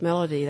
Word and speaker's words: Melody: My name is Melody. That's spelled M-E-L-O-D-Y, Demerit, D-E, capital M-E-R-I-T-T.